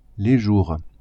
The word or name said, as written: jours